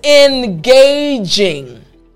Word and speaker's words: engaging: It's E-N-G-A-G-I-N-G. Engaging. Engaging. So I want to focus on the j sound. Engage. Jing, right? The J sound at the beginning of the jing.